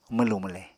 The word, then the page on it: mwlù-mwle: Apatani